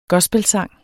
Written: gospelsang

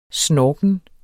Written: snorken

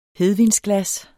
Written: HEDVINSGLAS

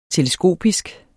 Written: teleskopisk

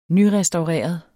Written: nyrestaureret

